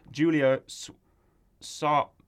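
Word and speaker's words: Julia Sawalha: Julia Sawala? – Yep. – I don't want to get that wrong. All respect to Julia Sawala, because she re-recorded her original lines from Chicken Run, and they were perfect.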